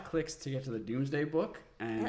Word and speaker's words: Domesday: So the first thing I need to cover about the Doomsday book is the spelling of the Doomsday book. So yes, the Doomsday book is spelled Domesday because it comes from Middle English, and the direct translation to modern English is Dooms.